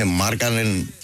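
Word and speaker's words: Markkanen: Τους Φιλανδούς πάντως τονίζουμε στην πρώτη συλλαβή. Οπότε έχω λέω καν στοιχηματική, ειδικά με αυτό έχουν κόλλημα. Ναι. Δηλαδή. – Για πες μας, μίλα μας. – Είναι Μάρκανεν, Χάκινεν.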